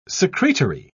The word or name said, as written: secretory